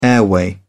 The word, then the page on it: airway: British English